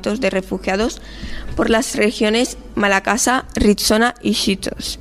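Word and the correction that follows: Schisto: Sí, se dice Esquistos.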